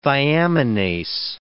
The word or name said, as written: thiaminase